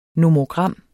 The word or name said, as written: nomogram